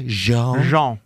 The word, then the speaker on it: Jean: We begin with the soft J. Jean.